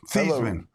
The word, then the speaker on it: Theismann: Joe Theismann. – Theismann. – Rhymes with Theismann.